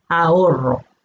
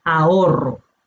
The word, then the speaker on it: ahorro: Para el estudiante de español la dificultad es la pronunciación, pues lleva una H entre la A y la O que no se pronuncia, y luego la doble R que para algunos es difícil de pronunciar, ahorro. – El mismo sonido que rock, perro, carro, Roma.